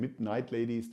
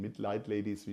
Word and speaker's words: Midnight Ladies: Bis kurz nach Mitternacht war volle Stimmung bereits am Freitag nach dem Fassanstich mit den Midnight Ladies, nicht den Midlight Ladies, wie ich das letzte Mal fälschlicherweise ausgesprochen habe.